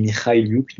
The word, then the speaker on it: Mikhailiuk: Voilà, j'ai fait un peu de prononciation, on dit Mikhailiuk, donc je me permets de faire le hipster prononciation.